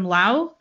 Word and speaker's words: Laos: So the people who were suffering from this puzzling ailment, they were typically refugees from Laos, a small land... Is that how you pronounce that? L-A-O-S? Yeah.